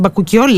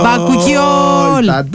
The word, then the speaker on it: Bakuchiol: Bakuchiol λέγεται, ωραίο, έτσι προφέρεται, παιδιά. Μ' αρέσει. – Μπαρμπάρι, Bakuchiol. – Bakuchiol.